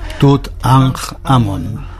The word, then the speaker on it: Tutankamón: Cuando he dicho Tutankamón, no sé si se lo pronunciáis vosotros así. – Tutankamón. – Bueno, pues así como tú dices.